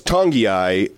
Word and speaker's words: Tongiai: Yeah, so it's Tongiai. There's a sort of a silent N from the islands. Every once in a while, you get the silent N before the G deal.